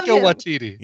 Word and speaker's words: Taika Waititi: Takiti Waki? – Yeah, Waka Tiki Taki. Yeah. – I love Taika Waititi.